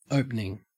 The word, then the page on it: opening: Australian English